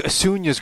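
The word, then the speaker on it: Acuña: Before you guys got there, he was like, "Where do you think Acuna's gonna go?" I'm like, "You mean Acuna, right?"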